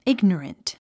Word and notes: ignorant: íɡnərənt; イグノラント

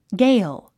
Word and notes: gale: ɡéil; ゲイル

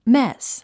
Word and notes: mess: més; メェス

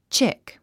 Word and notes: chick: tʃík; チィック

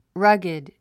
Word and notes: rugged: rʌ́ɡid; ラギド

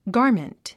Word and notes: garment: ɡάː r mənt; ガーメント

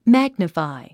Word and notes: magnify: mǽɡnəfài; マァグニファィ

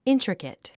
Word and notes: intricate: íntrikət; イントリケト

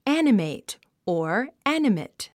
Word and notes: animate: ǽnəmèit; アニメト

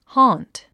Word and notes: haunt: hɔ́ːnt; ホーント

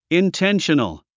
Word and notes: intentional: inténʃənl; インテンショナル

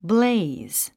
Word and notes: blaze: bléiz; ブレイズ

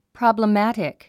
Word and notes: problematic: prὰbləmǽtik; プロブラマティク

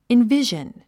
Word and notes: envision: invíʒən; インビジョン

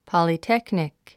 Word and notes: polytechnic: pɑ̀litéknik; ポリテクニク

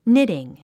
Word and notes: knitting: nítiŋ; ニティン

